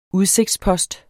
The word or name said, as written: udsigtspost